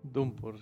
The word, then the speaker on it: Downpour: Pues me pasé Silent Hill Downpour, ¿se pronuncia así? – Downpour, sí.